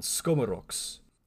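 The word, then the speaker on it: skomorokhs: skomoroks.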